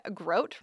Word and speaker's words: Grote: Stan Grote, rhymes with boat.